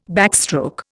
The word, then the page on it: backstroke: Indian